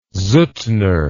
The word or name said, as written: Suttner